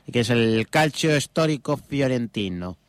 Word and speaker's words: fiorentino: Calcio hazlo. Histórico fiorentino. Hombre, pronunciación italiana. – Calcio histórico fiorentino, vale.